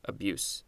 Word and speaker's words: abuse: So it's more like abuse and not abuse. Yes, in fact, I'm looking at the pronunciation. The pronunciation for the first one has an S at the end, and this second one has a Z, so it's abuse.